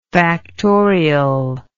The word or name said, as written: factorially